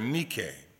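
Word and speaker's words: nike (νίκη): And the Greek word for overcomer is nike, or the way we like to pronounce it, Nike.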